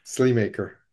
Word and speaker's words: Sleamaker: So tell us how you pronounce your last name so that we know that. – Sleamaker. – Sleamaker. Okay. See, I would have butchered that one. I would have butchered that one. I was thinking Slay. Yeah. Slay Mocker.